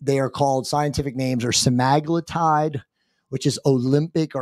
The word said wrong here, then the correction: Ozempic: or Ozempic.